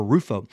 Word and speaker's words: Rufo: And I said it wrong earlier. I think it is Rufo, not Rufio. It's Rufo. I get in Rufio in my mind because of the Hook movie about Peter Pan and that Rufio kid with the red mohawk.